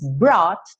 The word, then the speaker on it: brought: Brought, tem um GH mudo aí, então, ó, brought, não se preocupa, tá?